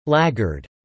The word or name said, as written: Laggard